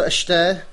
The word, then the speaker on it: Este: Jak se to čte? Čte se to Ešte.